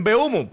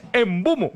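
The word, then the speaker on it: Mbeumo: que le decimos en Beumo, pues la gente joven va a decir, pero no saben Diego, cómo se pronuncia porque es Embumo.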